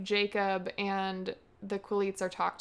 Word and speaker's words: Quileutes: I do want to say real quick, just so how do you say it? Quileutes? – Quileutes.